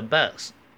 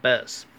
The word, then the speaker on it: bus: bus it's a bus，这是一台巴士，不要读成 it's a box，it's a bus。